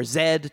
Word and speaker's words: Z: So that's The Lost City of Z or Zed depending on your pronunciation and that is available on Amazon Prime.